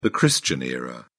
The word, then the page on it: christian era: British English